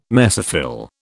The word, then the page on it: mesophyll: British English